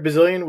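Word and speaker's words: Bazilian: Eric Bazillion, was it? – Yeah.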